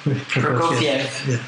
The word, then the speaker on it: Prokofiev: So how do I pronounce Prokofiev? – Prokofiev. Yeah.